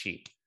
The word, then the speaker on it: Qi: It's spelled Q I, but it's pronounced chi.